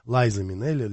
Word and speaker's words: Лайза Минелли: Лайза Минелли, Лиза Минелли, как говорится, как произносить, по-английски или по-русски.